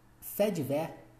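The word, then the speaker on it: faits divers: Fates Divers pronuncia-se Fedver, ou seja, eu já li errado no começo. Pronuncia-se Fedver. Fedver.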